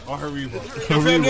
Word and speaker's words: Haribo: Haribo. – Haribo.